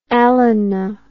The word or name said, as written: Alannah